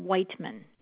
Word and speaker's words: Wightman: The last name is spelled W-I-G-H-T-M-A-N, Whiteman, Patricia.